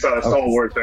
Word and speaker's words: Stallworth: It's Charles Stallworth. Y'all got my name wrong on that one, but it's Stallworth, S-T-A-L-L-W-O-R-T-H, and it's at metal_gear88 at that's my Twitter, and I have my YouTube channel and everything on that.